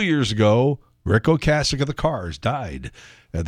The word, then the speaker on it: Ocasek: And she said, it's Ocasek.